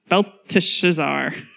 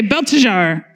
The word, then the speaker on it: Belteshazzar: How would you say that? Belteshazzar. Everybody say Belteshazzar, but I'm going to call him Old Belty.